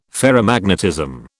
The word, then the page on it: Ferrimagnetism: British English